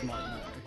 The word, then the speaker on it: Martinet: It is Martinet.